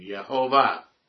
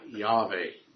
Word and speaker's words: Jehovah: But I finally learned, praise God, the correct pronunciation of his name, Jehovah. Not Yahweh. Yahweh was a Samaritan pronunciation.